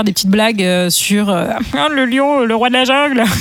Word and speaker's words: Lyon: Ça s'écrit avec un Y. – Lion, comme la gare de Lyon.